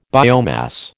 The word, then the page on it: biomass: American English